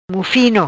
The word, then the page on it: Mufino: Patuá